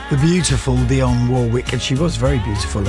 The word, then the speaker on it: Dionne Warwick: And she was one of those people that had a real aura, an aura of, I am Dionne Warwick, and she told me that's how I must pronounce her name.